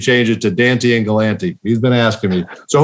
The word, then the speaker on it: Galante: And hearing many of his stories, he actually told us, you know, how you actually say his name properly, and it's not Galante, Galante.